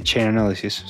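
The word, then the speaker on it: Chainalysis: salió un reporte de Chain Analysis muy interesante, yo no sé si se diga así, ¿se dice así? Chain Analysis. – Sí, Chain Analysis, sí.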